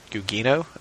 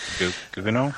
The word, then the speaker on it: Gugino: And it was also kind of funny to me that the lady playing Silk Spectre 1, Sally Jupiter, who was Carla Gugino, I think is how you say her last name. – Gugino? – Yeah, something like that.